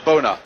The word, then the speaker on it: Bonner: To Pat Bonner, boy from Donegal, where they tell me, in fact, you should pronounce it Bona.